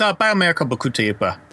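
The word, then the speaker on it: Bio Miracle Bokutte Upa: Probably pronounced that incorrectly. – Yeah, basically. But it's Biomerica Bukute Upa.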